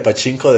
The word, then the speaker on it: pachinko: Yo creo que es peor que el pachico que sacaron, ¿no? ¿Cómo se pronuncia esa pinche máquina? – Sí, la máquina. Sacaron una máquina de pachinko de Silent Hill 2, de hecho.